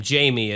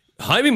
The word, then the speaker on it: Jaime: And Jaime Musan, which is actually funny enough, we would pronounce his name Jamie. It's J-A-I-M-E, but I guess with Spanish it's pronounced Jaime, whatever.